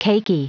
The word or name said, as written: cakey